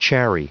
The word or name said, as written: chary